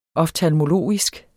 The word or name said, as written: oftalmologisk